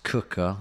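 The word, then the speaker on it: Quooker: It's spelt Quicker, but pronounced Cooker.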